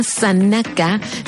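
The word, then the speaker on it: Zanaka: El disco se llama Sanaka, se escribe Z-A-N-A.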